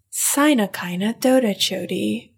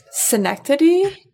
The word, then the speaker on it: synecdoche: Our favorite is Sinakina Dodachodi, which is synecdoche,